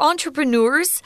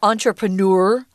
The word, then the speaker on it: entrepreneur: They're entrepreneurs. You can pronounce this word two ways entrepreneur or entrepreneur. It's up to you.